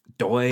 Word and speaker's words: Doye: That just sounds Doy. – How could that not be? Spell that. – D-O-Y-E. Nicholas Doy.